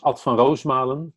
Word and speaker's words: Ad van Roosmalen: My name is Ad van Roosmalen, to pronounce it in Dutch. But I think Van Roosmalen is maiden is very close.